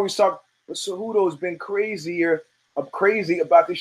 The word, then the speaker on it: Cejudo: So when I see the former crown-wearing, cape-wearing lunatic, Henry Cejudo, it's pronounced Cejudo, take my word for it, standing in the cage after the fight where people are cheering, And he's caught up in the moment.